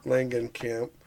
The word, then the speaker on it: Langenkamp: Langenkamp.